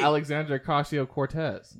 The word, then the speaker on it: Alexandria Ocasio-Cortez: Alexandria Ocasio-Cortez.